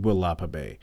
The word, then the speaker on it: Willapa Bay: And if you're wondering where I went camping, so I went to, I keep pronouncing this wrong, is Walipa Bay or Willapa Bay? I think it's Willapa Bay in Washington.